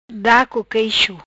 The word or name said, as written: Da co queixo